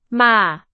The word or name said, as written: 马